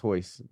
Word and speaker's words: Royce: A Royce Gracie. – That's Hoyce. That's how you pronounce it.